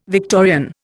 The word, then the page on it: Victorian: Indian